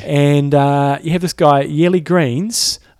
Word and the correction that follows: Geens: Geens, not Greens.